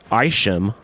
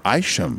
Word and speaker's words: Isham: Lars pronounced it Isham. – Isham. – We've been saying Isham.